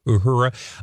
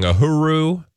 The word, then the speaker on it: Uhura: For some reason, I grew up always saying Uhuru. – It happens. – It clearly not. So every time I try to say it correctly, I always sound like I'm um, saying the wrong thing.